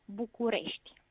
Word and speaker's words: București: un pastor que se llamaba Bucur para pronunciarlo en rumano, um, Bucarest sería București, con lo cual se parece a Bucurie, que es alegría, y a Bucur, que es el nombre del pastor.